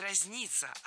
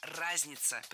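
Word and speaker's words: разнятся: По цивилизациям это разница. – Нет, не разница, а разница. Представляете, в этом слове ударение должно падать на первый слог.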